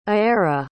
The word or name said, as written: Aaira